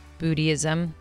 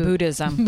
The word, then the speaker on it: Buddhism: And I, I love when I meet people who practice Buddhism. Did I say that right? – No, Bu- Buddhism. Buddhism. Buddhism. Yeah, Buddhism.